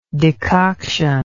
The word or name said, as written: decoctive